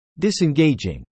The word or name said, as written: disengaging